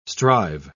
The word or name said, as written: strive